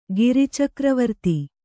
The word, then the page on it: ಗಿರಿಚಕ್ರವರ್ತಿ: giri cakravarti